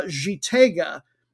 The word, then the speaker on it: Gitega: It was getting back, moved back to a, the geographic center of the country to a city called uh, Gitega, which uh, I believe I'm getting the pronunciation right if they pronounce it the French way there.